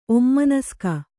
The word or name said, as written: ಒಮ್ಮನಸ್ಕ